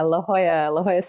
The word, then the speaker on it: La Jolla: I was reading in your bio on your website that you were the principal cellist with the La Jolla Symphony, if I'm pronouncing that correctly. – Yeah, La Jolla Symphony. – Oh, La Jolla. There you are. Yeah, okay, butchered that one.